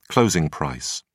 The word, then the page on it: closing price: British English